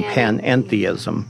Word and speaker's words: panentheism: Panampheism. – Right. I said that wrong. Panentheism.